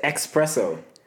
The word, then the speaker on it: espresso: I say espresso. – There's no X.